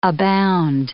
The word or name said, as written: abound